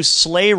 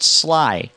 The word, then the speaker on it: sleigh: So they go to do Sleigh Ride. – In English. – But they can't pronounce sleigh, they call it sly.